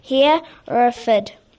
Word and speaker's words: Hereford: Hereford.